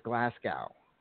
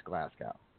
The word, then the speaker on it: Glasgow: Is it glass glow? Is it glass glow or glass glue? – It's Glasgow. Pretty sure it's Glasgow. – Now, how's the gal? It ain't gal, G-A-L. It's glass glow.